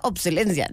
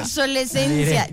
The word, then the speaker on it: obsolescencia: Pues es que es como obsoleto, obsolencia, no obsolescencia. Pero sí, Lucho tiene razón. ¿Quién lo iba a creer? – Yo soy remenso, pero tengo mis días. – Obsolescencia. Creo que lo he dicho mal todo el tiempo.